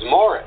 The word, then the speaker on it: Maurice: He just, well, you know, and it's so weird because, you know, the British or the English pronunciation of Maurice is Morris, you know.